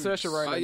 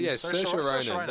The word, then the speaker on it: Saoirse Ronan: Saoirse Ronan. – Saoirse Ronan.